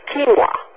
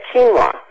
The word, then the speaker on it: quinoa: But the other one that I like for pasta is, and I may not be saying it right, is quinoa. – Q-U-I-N. – Quinoa. – That's yeah, quinoa. I love that pasta. And I looked online, to check your ingredients. I think mine was quinoa and corn.